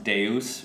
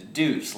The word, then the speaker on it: Deus: And we found the word, it's like pronounced Deus, right? Which is essentially God in Latin, right? But when we took that name, which is spelled D-E-U-S, yeah. – And I was a freshman in high school, and everybody was like, your band name is Deuce.